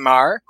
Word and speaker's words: Mar: Plus, consistent colors throughout by Marte Gracia, and letters by the amazing Ariana Mar, who, by the way, wants you all to know that it is pronounced like it's in Boston. Mar, right? That's how you say her last name.